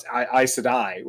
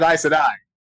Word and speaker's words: Aes Sedai: Or I'm having a debate with one of my friends about how you pronounce Aes Sedai, which is the magic using characters in the Wheel of Time books.